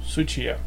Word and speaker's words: Tsuchiya: Eight volumes, it went from 1996 to 1998, written by, I hope I'm going to pronounce this guy's name right, Garon Tsuchiya? – No. – Tuchia? Suchia. Suchia. Hmm.